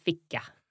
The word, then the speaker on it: ficka: So you have a long E sound in fika. So E. And in ficka, I.